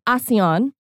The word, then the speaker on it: ASEAN: today or tomorrow is the kickoff of the ASEAN summit, or ASEAN, as everybody else in the world calls it, but most people in the U.S., because, it's spelled A-S-E-A-N, so Americans tend to say A-Sean.